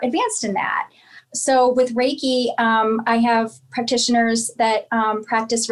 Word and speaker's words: Reiki: Reiki or Reiki? – Reiki, yes.